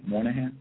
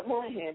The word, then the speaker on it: Moynihan: You touched on it earlier, Mr. Glazier and Moynihan. Am I pronouncing his name correctly, Moynihan? – Yeah, Moynihan, Daniel Patrick Moynihan.